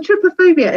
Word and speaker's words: trypophobia: I believe it's pronounced trypophobia, but I'll stand corrected. Chrissy Giles, tell us about this syndrome. And first of all, is that how you say it? Trypophobia? – That's how I say it. – Okay. – No one's corrected me yet.